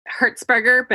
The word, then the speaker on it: Herzberger: And I said that wrong too, didn't I? – Herzberger, but. – Herzberger. – Any variation of that works.